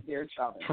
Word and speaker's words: Derek Chauvin: This week starts the Derek Chauvin, I don't know how to say his name, Derek Chauvin.